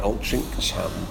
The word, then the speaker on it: Altrincham: So Altrincham, it has an improbable name as well, because it's spelt Altrincham, so it sounds like Altrincham. But there is an old stone monument with the word Altrincham with a G in it, which perhaps is where the Altrincham pronunciation comes from, because usually you probably got to say Altrincham, but you sort of shorten that cum sound.